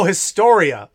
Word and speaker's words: Historia: Oh, Historia. Okay. Oh, yeah. I thought you were pronouncing the word history in a weird way. I thought you were saying history.